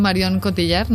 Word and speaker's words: Marion Cotillard: Me gusta mucho, por ejemplo, Marion Cotillard.